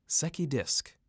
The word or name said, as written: Secchi Disk